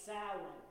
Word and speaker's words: Samhain: Halloween's origin dates back to the ancient Celtic festival of Samhain. That's spelled S-A-M-H-A-I-N. It doesn't sound the way it's spelled.